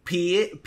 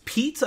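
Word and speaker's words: Paet: It's uh I, I, you know, I always call this guy uh, Piet, Piet, but it's Pete.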